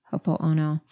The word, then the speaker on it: Ho'oponopono: and it actually connects to the Ho'oponopono prayer. I may not have said that correct. Every once in a while, I leave out a syllable. Ho'oponopono.